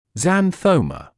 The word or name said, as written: xanthoma